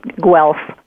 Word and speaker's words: Guelph: And pardon me, if I didn't pronounce that town correctly. – Yeah, it's a tricky one. It's pronounced Guelph.